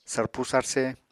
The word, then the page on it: sarpu sarse: Apatani